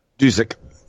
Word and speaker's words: Dusek: Is it Joe Dusek, is it? Or Dusek. – Dusek, okay.